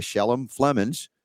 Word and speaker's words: Shelem Flemons: And this is by Shelem Flemons.